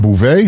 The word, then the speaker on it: Beauvais: So Garcelle Bouvard, Beauvais, how do we say her name? Fancy. No, no, you said it.